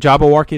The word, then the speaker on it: Jabberwocky: Yeah, actually, the Jabberwocky in Jabberwocky, is that how you say it? – Yes.